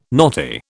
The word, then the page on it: knotty: British English